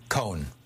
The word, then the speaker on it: Cohen: Then finally McCarthy and Ray, you said Ray Kahn or Ray Cohen? – Cohen. One syllable.